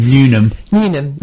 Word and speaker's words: Newnham: Maidstone's new state-of-the-art medical facility at Newnham, how do I say it? – Newnham, as I said it, close to the M20, is already creating jobs for local people.